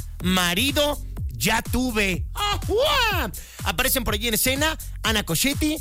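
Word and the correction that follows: Ana Ciocchetti: Isabela Camil, Ana Sciocchetti, perdónenme, lo dije mal. Ana Sciocchetti, Isabela Camil y Sabine Mussier.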